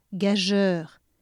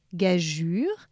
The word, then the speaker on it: gageure: Ensuite, gageure, c'est un nom féminin. On part de gage, on ajoute ur » et hop, gage plus ur »,« gageure. Puis, dégingandé, puisque gin, G-I-N, ça fait gin, hein, comme dans gingembre.